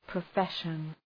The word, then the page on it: profession: prə'feʃən